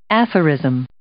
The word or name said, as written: aphorism